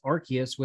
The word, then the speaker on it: Arceus: According to the anime dubs, then voice actor Tom Whalen, the dub chose to pronounce Arceus with a hard C instead of a soft C due to concerns over the latter's pronunciation potential implications in British English speaking territories.